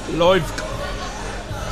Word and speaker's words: Loivka: It's Loivka. So I combine the word love and the word like, make it one word.